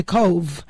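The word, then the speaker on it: Cobh: they changed it to Cove, spelled like Siobhan. The V is B-H, C-O-B-H.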